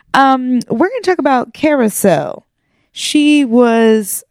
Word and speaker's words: Karisselle: Spelled something like K-A-R-I-S-S-E-L-L-E. – That's what you have in the notes. – Yeah, but it's pronounced Carousel.